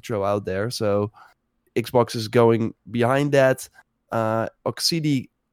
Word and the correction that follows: Oxide: Games. Am I pronouncing that right, do you think? – Oxide, I think. – Oxide Games, yeah.